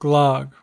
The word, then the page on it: glogg: American English